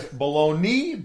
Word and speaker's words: bologna: I think his last name's M-A-Y-E-R. – I think you're right. But it's also known as Baloney, Balona, so you can say either one. And whoever, you know, saw B-O-L-O-G-N-A and said baloney, I'm not sure why it's pronounced that way and not Bologna, but there we go.